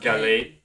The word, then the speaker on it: Gallade: How to pronounce Gallade. – Not bad, yeah. Gallade.